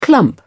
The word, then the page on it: clump: British English